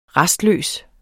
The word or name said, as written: rastløs